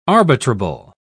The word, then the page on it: arbitrable: British English